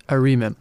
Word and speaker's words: arimim: Okay, and the sweet one is called how do you say that? Arimim. Arimim. Okay, so arimim. That actually sounds sweet.